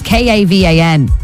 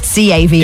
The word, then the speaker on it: Kavan: It's Kavan, not Cavan. – Yeah. Not like the county.